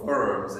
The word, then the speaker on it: Worms: So the diet, or this official tribunal, we might say, of Worms, or Worms in German, because of the W is pronounced as a B in German.